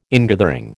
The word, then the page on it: ingathering: American English